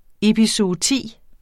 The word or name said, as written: epizooti